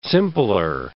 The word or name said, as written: simpler